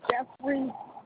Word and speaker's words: Jefferies: Lois Jeffrey.